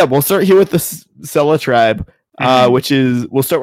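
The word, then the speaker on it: Sele: Sela. It is pronounced Sela, but if you want to remember it, it's sort of like Seal, and seals live in the blue ocean.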